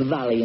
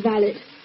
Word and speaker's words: valet: How is it your father employs a valet and you don't know how to pronounce it? – It is valet. It's spelled V-A-L-E-T. – Which happens to be a French word and is pronounced valet. – Bran, how do you pronounce V-H-A-L-E-T? – Shallot, I suppose.